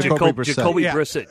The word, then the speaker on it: Brissett: is it Brissett? Is that how you say it? – Yeah, Jacoby Brissett.